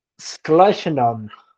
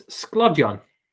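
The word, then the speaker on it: sglodion: Please pronounce this Welsh word for me, spelled S G L O D I O N. S G L O D I O N. – Schleichenon. One more time. Schleichenon. It's Sklovjon. Ah, that's not that's not terrible.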